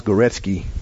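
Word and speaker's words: Górecki: But months ago, I was introduced to an individual by the name of Henrik, and it's pronounced Goretzky, but it's spelled G-O-R-E-C-K-I.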